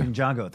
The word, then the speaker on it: Ninjago: Ninjutsu? – Ninjago. – Ninjago, thank you.